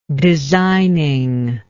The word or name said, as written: designingly